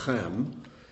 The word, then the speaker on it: Ham: Ham, it's not Ham, it's actually Chem, is a ch sound, means heat.